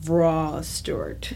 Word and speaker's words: Wraae-Stewart: It's like a V... Like, if it was phonetically, it would be like a V-R-A-W, but it's... – Oh, Wraae-Stewart. – But it's spelled like Ray.